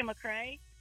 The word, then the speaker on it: McCrea: The Facebook page is just Rebecca McCray, and the last name is spelled MCCREA.